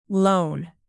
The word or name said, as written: loan